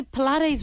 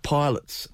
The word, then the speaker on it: Pilates: It's like the Pilates moves. Like what are that. They called? The... – Yeah, so I'm sure I've never done Pilates. In fact, until yesterday, I pronounced it pilots.